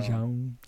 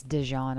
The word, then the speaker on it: Dijon: Wait, Dijon or Dijon? – Dijon. I think it's Dijon. I think I was saying it wrong. – You were. It's Dijon. Dijon.